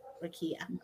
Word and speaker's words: Rakia: Yes, Rakia.